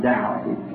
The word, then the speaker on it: Daugh: I said, Brother Dow, can you hear me? He looked up at me. Not really in his name. He's a German. D-A-U-G-H. And I was pronouncing Dow.